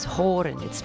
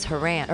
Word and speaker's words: Horan: Our surname was Horan. It's spelled H-O-R-A-N. Horan. And I always thought it was Haran, or H-A-R-A-N. I always thought it was pronounced Haran.